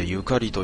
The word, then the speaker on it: 縁: 縁と書いてゆかりと読む。